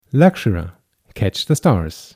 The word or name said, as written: Luxcera